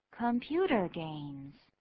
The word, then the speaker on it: computer games: Computer games，这两个字都是名词。第一个名词当做一个形容词来修饰第二个名词，那第二个名词被修饰的那个名词没有重音，念作 computer games。